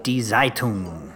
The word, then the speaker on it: Zeitung: Make sure when you say Zeit, not to say it like it begins with an S. It's not Zeitung, it's Zeitung. – The Z has a little T sound in front of it? – Yeah, it does.